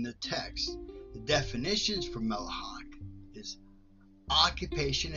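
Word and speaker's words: Melacha (מלאכה): Melacha.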